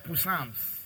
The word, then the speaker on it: Psalms: While they were growing up, that's how they wake up and say, "We read our Psalms." Chase Alex, I say Psalms. You know Psalms? Psalms. She's pronouncing the P. She didn't know then, it was silent.